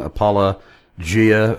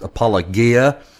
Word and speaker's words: Apologia: I know that our friend, our mutual friend, James White, always makes fun of you mispronouncing Apologia, which he says it should be rightly pronounced Apologia.